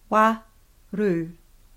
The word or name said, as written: waru